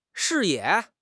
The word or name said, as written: 视野